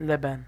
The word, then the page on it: Leben: American English